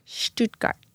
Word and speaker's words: Stuttgart: My first question. Stuttgart. Did I say that right? – You are the best.